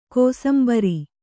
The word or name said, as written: ಕೋಸುಂಬರಿ